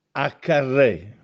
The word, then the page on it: Accarrè: Castellanetano